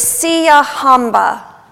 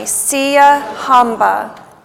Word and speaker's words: Siyahamba: The first word is Siyahamba. Say that with me, Siyahamba, Siyahamba. Siyahamba means that we are marching. So when we sing in English, we are marching. In the Zulu, we sing Siyahamba, Siyahamba.